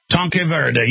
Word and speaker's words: Tanque Verde: Tanaquay, Verde, and all the, how do you pronounce it? Tanque Verde.